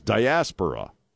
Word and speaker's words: diaspora: And it is diaspora, is how you say it.